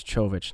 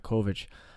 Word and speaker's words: Čović: You know, I think this was a great debut performance for Ante Čović, and I believe that's how you say his name, is Čović, not Kovic. It kind of set up in a 3-5-2 of sorts with,